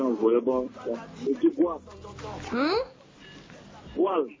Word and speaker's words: goal: Donc, comme c'est volleyball, là, on prononce en anglais goal.